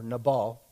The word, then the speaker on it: Nabal: Now the name of the man was Nabal, or Nabal, is how you pronounce it in Hebrew, and the name of his wife, Abigail.